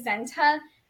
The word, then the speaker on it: Senta: That same year, we had an au pair whose name was Zenta, but it was spelled S-E-N-T-A. Obviously, Santa is spelled S-A-N-T-A,